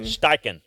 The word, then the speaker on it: Steichen: First up, we see is it pronounced Steichen? – Steichen, yes.